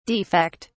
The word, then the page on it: defect: British English